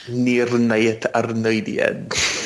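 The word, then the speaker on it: Nirnaeth Arnoediad: Nirnayet Arnoidien.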